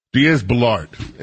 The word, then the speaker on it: Diaz-Balart: Diaz-Balart is his name.